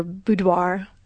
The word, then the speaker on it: boudoir: For those that don't know what boudoir is actually a bedroom setting. And there was a conversation that we had. You may hear boudoir and boudoir. Boudoir is the French pronunciation, which we chose to go with.